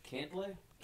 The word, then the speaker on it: Cantlay: Cantlay? – Is that it? – Yeah. – I just Patrick Cantlay? – Well.